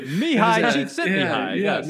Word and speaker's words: Mihai Csikszentmihalyi: And give that, you guys have heard the concept of flow by Mihai Csikszentmihalyi. – Of course, yeah, love it. – Good job on the pronunciation. – Yeah, exactly. You know someone's a scholar, right, man? If they Mihai Csikszentmihalyi.